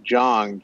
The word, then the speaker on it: Zhang: Well, he's got a lot of talent, and, and just so you know, which I can't figure out, his name is spelled Z H A N G, but it's pronounced Jong, J O N G.